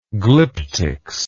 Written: glyptics

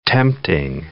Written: tempting

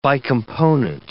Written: bicomponent

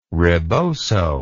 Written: ribozo

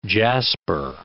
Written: jasper

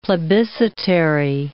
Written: plebiscitary

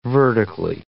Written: vertically

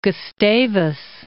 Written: Gustavus

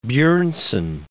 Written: Bjornson

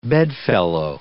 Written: bedfellow

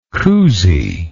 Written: Cousy

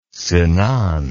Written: Sinan

